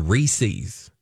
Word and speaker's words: Reese's: They didn't know, apparently, that the correct pronunciation of Reese's peanut butter cups is Reese's.